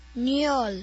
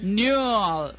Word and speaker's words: Null: Will tell us the correct pronunciation of zero in German. – Null. – Null. Well, null was absolutely acceptable.